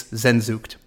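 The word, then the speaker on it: Sehnsucht: This is my podcast, and I'm telling you, I am the master of all German pronunciation. So it's Zenzucht.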